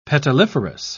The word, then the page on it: petaliferous: British English